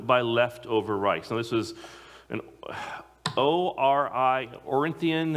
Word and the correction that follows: ornithologist: the bird specialist. They just, it's a myth. Ornithologist, thank you.